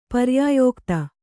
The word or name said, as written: ಪರ್ಯಾಯೋಕ್ತಿ